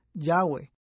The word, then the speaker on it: Yahweh: Recientes descubrimientos arqueológicos han indicado que este término debe ser pronunciado Yahweh.